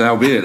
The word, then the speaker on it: albeit: says the guy that pronounced it, albeit. – Yeah, because it's pronounced albeit, that's why.